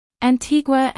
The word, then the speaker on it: Antigua: Antigua and Barbuda.